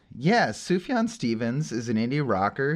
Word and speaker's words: Sufjan: The J, it's not. I was gonna say the J is silent, but it's really not silent. It's a Y. – It's a Y. Yeah. Sufjan.